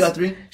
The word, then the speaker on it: Guthrie: But Janet Guthrie, I believe I got that right. – That's right, Janet Guthrie.